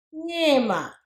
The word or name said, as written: Ngiyima